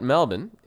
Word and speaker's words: Melbourne: So Melbourne, first of all, which I guess for all of you, you know, American listeners, Melbourne, perhaps yeah. maybe, you know, you may recognize that pronunciation, but actually it's pronounced, if you go to Australia, they pronounce it Melbourne.